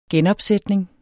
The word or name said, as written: genopsætning